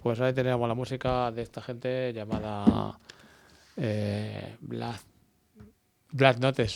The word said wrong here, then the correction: Black Notes: suelo decir yo. Pero no sé, pero bueno. ¿Cómo se pronuncia, Estela? – Black Notes. – Ah, muy bien, así, muy bien.